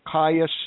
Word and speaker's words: Chayah: So Chayah has a has a C H at the beginning. It's it's uh, pronounced with a ka sound, but it's but it's c h a y a h org.